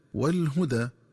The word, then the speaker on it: والهدى: قراءتها بضم الهاء وفتح الدال ثم ألف مقصورة هكذا والهدى.